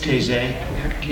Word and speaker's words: Taizé: Just recently I was introduced to, I hope I pronounced this correctly, T-A-I-Z-E, Taizé. – Taizé, in France, And we did a lot of repeating of phrases and singing.